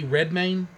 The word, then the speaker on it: Redmayne: And then, is that Eddie Redmayne? – Yeah.